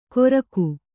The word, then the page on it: ಗೊರಕು: Kannada